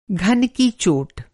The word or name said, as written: घन